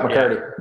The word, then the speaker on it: McHardy: Yeah, McHardy.